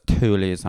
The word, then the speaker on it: Thule: I want those Thule's on my fucking car. – Oh, you said it right. Congrats.